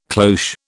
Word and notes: cloche: British English

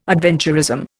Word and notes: adventurism: Indian

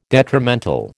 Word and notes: detrimental: American English

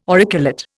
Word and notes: auriculate: Indian

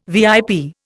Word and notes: VIP: Indian

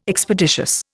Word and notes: Expeditious: Indian

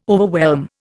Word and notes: overwhelm: Indian

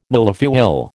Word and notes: millefeuille: American English

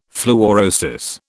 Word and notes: fluorosis: British English

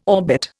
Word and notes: orbit: Indian

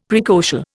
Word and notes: praecocial: Indian

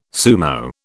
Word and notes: sumo: British English

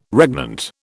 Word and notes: regnant: British English

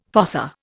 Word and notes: fossa: Indian

